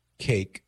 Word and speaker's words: CAIC: He says, a Congressional Abortion Industrial Complex, CAIC, pronounced cake.